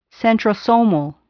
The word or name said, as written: centrosomal